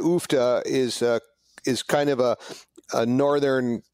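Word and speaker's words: Uff da: We are currently drinking, correct me on the pronunciation, is it Ufta or Ufta? – Ufta. Ufta is a Norwegian expletive.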